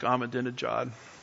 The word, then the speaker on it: Ahmadinejad: And the former leader of Iran, Ahmadinejad, how do you say Ahmadinejad? Just say genocide really fast and it sounds like Ahmadinejad.